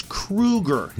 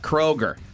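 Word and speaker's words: Kroeger: According to Chad, It's Kruger, not Kroger. – He spells it with an O, though. O-E. – Yep, R-O-E-G-E-R, but he says it's like K-R-O-O-G-E-R. That's how you pronounce it.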